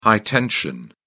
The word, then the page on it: high tension: British English